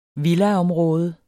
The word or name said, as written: villaområde